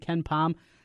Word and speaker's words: KenPom: In terms of experience at KenPom, I think they're like the 325th least experienced team. Did I say that right? – Yep, you did.